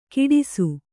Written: ಕಿಡಿಸು

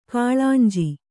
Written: ಕಳೆಂಜಿ

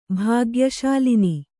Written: ಭಾಗ್ಯಶಾಲಿನಿ

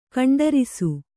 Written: ಖಂಡರಿಸು

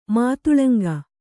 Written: ಮಾತುಲುಂಗ

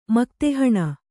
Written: ಮಕ್ತೆಹಣ

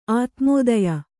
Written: ಆತ್ಮೋದಯ

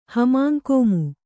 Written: ಹಮಾಂಕೋಮು

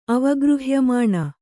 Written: ಅವಗೃಹ್ಯಮಾಣ

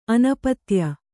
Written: ಅನಪತ್ಯೆ

